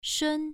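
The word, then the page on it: shun: tone 1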